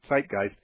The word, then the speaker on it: Zeitgeist: One of the most famous and influential websites that has pushed this is a video called Zeitgeist. Zeitgeist is spelled Z-E-I-T. It's German. G-I-E-S-T. Zeitgeist.